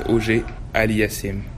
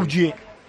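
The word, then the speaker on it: Auger-Aliassime: ¿No prefieres que el propio Félix te diga cómo se dice su nombre? – Félix Auger Aliasim. – Auger. – Auger. – Auger Aliasim. – Auger. Muy francés, ¿no? – Creo que jamás lo volveré a decir así.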